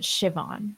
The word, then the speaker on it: Siobhan: Because it's spelled like Siobhan. – Yeah, it made no sense. – But you pronounce it Siobhan.